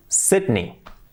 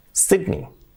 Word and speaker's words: Sydney: Al menos aquí en España decimos siempre Sydney, y no es Sydney, es Sydney. Sydney. ¡Wow! Sydney. Sydney, con I.